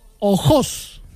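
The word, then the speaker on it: Ojós: Bueno, pues llegamos al municipio de, y lo voy a pronunciar como se debe pronunciar, Ojos. – Ojos. – O-J-O-S con tilde en la segunda O. Ojos.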